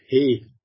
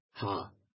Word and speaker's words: He: Also im Hebräischen wird das Ta als He ausgesprochen, nicht als Ha.